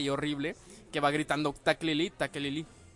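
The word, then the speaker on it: Tekeli-li: Hay unos nativos ahí que hacen un ruido que es como taquelili, taquelili, algo así, bueno, así se escribe. Y al parecer el Cho'Goth, H.P. Lovecraft le dio el mismo sonido al grito que da el Cho'Goth, que es taquelili, taquelili.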